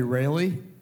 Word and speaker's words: Raleigh: Well, my name is spelled R-A-L-E-I-G-H. It depends on how old you are, of how you pronounce it. Some people say Rayleigh. If you're old enough, you'll say Raleigh.